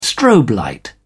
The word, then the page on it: strobe: British English